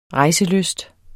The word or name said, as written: REJSELYST